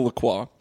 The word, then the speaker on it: LaCroix: LaCroix. – I have a LaCroix. That's how it's pronounced.